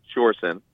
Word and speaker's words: Sjurjen: So the J is silent, so it's pronounced Sjurjen, and I've been correcting people since kindergarten, so don't worry about it at all.